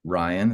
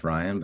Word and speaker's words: Riun: I think the most important thing again is just to know how to spell my name, R-I-U-N, Ryan. It's pronounced Ryan, but R-I-U-N Garner, G-A-R-N-E-R.